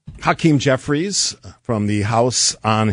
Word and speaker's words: Hakeem: I wanted to give equal time to Hakeem Jeffries. – What was his name? – Hakeem. – There you go. – What did I say? Hakeem. – You added a syllable.